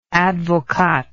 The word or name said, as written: advocaat